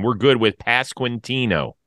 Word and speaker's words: Pasquantino: It's actually a relatively easy name to say. Like, you look at it and you think, "Oh man, this is gonna suck." But it's just Pasquantino, just how it sounds or how it's spelled.